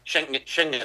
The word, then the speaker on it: Schengen: ¿Cómo se pronuncia? Sí. – Schengen.